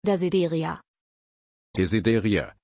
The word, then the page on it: desideria: German